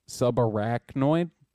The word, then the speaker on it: subarachnoid: I think you're pronouncing that word wrong, but I did. – Subnecroid. – Arachnoid? I mean, that's how it's SUBARACHNOID.